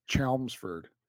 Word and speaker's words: Chelmsford: Gary says it's Chelmsford.